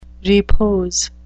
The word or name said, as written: repose